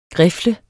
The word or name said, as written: grifle